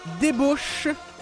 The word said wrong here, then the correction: Débauche: Puis là, écoute, j'ai pas été correct, j'ai dit « débouche », mais je vais le prononcer comme il faut, là, je vais être correct, là. Débauche mobile, comme dans Vie de débauche.